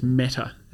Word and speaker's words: metta: And the term here is metta. Have I said that right? – Yeah, so metta is loving kindness, effectively, is probably the best translation of it.